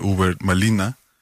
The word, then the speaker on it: Hubert Malina: Y bueno, recientemente he estado leyendo la poesía de Hubert Malina, que es como se pronuncia, ¿no? Y bueno, es como difícil. – Ah, Uber. – Por cómo se escribe el nombre, ¿no? Que es Hubert Matihuá, pero la pronunciación es Malina.